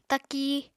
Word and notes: taki: apatani

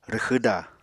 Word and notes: rwxwda: Apatani

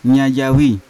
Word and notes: nyajawi: Yawa

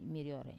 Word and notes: marior: Wamesa

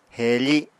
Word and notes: heefi: apatani

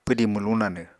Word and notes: pwdi mwluñ nanw: apatani

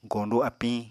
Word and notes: goñdu apiñ: Apatani

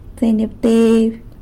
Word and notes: tseinyabteby: sanlucasquiavini

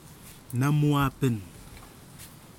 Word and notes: namwapen: kwamera

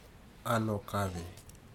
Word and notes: alokave: futuna aniwa

